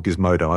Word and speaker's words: Gizmodo: In the test that Gizmondo did, or Gizmodo, I always mispronounce that.